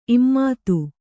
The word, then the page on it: ಇಮ್ಮತ್ತಿ: immatti